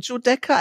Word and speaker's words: Giudecca: Du hast es fast richtig ausgesprochen, Giudecca,